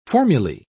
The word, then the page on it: formulae: British English